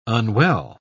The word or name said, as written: unwell